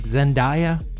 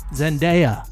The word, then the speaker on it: Zendaya: From Johnny Davis, we just learned it is not pronounced as papaya, like Zendaya. It is Zendaya or Trendaya.